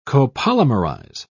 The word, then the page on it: copolymerize: British English